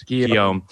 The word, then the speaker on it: Guillaume: Guillaume.